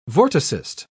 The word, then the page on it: vorticist: British English